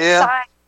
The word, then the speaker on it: Scythe: Maybe it's scythe. Yeah, I think it's Scythe is the name of the book, actually. – Yes, I'm sorry. – It is. – And I mispronounced that earlier, everyone.